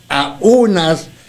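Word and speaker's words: aúnas: Si a eso le aunas, Toño, si a eso le aunas, acento en la U, aunas,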